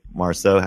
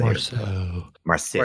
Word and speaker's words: Marceau: And like the character Sophie Marceau, how do you say Marceau. – Right, so like Marceau.